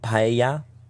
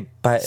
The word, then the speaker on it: paella: was pronouncing it wrong. So I'm going to say that he said it. Paella? – No, it's Spanish. Paella.